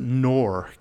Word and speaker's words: Knorr: There was a guy, let's see, I think after Rutherford died, there was a guy named, I think you would pronounce it Knorr, K-N-O-R-R, okay?